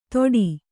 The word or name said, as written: ತೋಡಿ